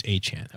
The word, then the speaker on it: Achane: He said Achane because that's the pronunciation guy. That was the clip on McAfee, and then Achane corrected, and then... It was like Achane told Peter King on Sunday night that it's Achane.